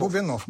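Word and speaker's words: Бубеннов: И после всего этого, я так не делаю монтаж, позвонил, всем путаю ударение, Бубенов, да? Или Бубенов? – Бубенов. – Бубенов, да, писатель.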